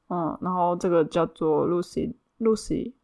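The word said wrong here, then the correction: ruisleipä: Louis Leba。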